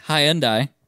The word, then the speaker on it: Hyundai: It's a 2006 Hyundai. That's how you say it, by the way. – Hyundai. – Tiburon.